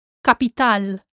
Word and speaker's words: capital: Capital. Capital. Again, remember that the stress is on the last A.